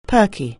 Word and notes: perky: anglais

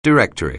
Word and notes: directory: anglais

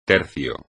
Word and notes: tercio: espagnol

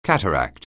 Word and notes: cataract: anglais

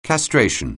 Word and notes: castration: anglais

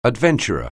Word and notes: adventurer: anglais

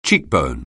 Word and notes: cheekbone: English

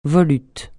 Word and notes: volute: francais

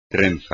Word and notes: trenza: espagnol

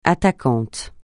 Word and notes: attaquant: francais

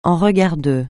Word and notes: en regard de: français

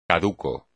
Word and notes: caduco: espagnol

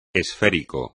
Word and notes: esférico: espagnol

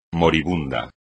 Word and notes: moribundo: espagnol

